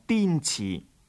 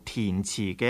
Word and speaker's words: 滇池: 南诏国王出自乌蛮，据近代的史学家考证，乌蛮是今日云南彝族的祖先，但是云南中部滇池或者有人读作填池的一带，百万的势力雄厚，百万即是今日云南白族的祖先。